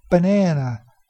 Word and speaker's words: banana: So we, for example, in English we say banana, banana. If I want to eat a banana, banana. You hear that stress? In the second syllable is na, banana. That second syllable is longer, higher pitched, and even a little bit louder.